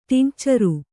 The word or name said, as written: ಟಿಂಕ್ಚರು